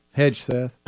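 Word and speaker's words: Hegseth: How you say his name? – Hedgeseth.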